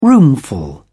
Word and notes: roomful: British English

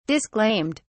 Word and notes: disclaimed: British English

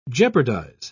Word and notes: jeopardise: British English

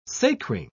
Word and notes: sacring: British English

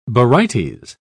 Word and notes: barytes: British English

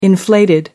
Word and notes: inflated: American English

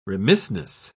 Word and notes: remissness: British English

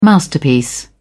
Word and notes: masterpiece: British English

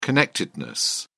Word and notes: connectedness: British English

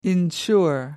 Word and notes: ensure: American English

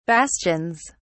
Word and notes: bastions: British English

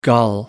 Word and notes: gull: American English

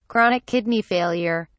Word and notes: chronic kidney failure: British English

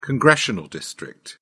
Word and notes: congressional district: British English

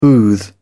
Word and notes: booth: British English

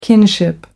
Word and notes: kinship: American English

